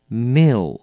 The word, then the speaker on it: milk: 这个字呢，在这边呢老师要提醒大家一下，发音的时候要特别的注意，它是念成 milk milk, 所以要记得了是 mil 后面有一个 L 的 L 的音，要念成 milk, OK, milk, 很多人很快的念掉就 milk, milk, 这样是不对的，是有一个 milk, 这样可以吗？